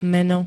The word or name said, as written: meno